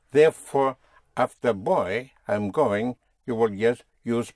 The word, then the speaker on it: voy: I am going, in Spanish, is boy. Oh, boy. Boy, oh, boy, oh, boy. Boy, boy. Spelled V-O-Y. Boy, yes, I'm going. Boy. Boy. Again, the V, pronounced with your lips, like a B.